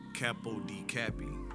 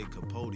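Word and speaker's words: Capo de Capi: That's how they say it. Capo di Cappy. – Oh, okay. – But I say Capote because I ain't Italian.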